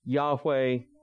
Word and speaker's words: Yahweh: Yahweh is how we would pronounce it. That is an approximate pronunciation. It's very difficult in the Hebrew to determine how exactly it was pronounced.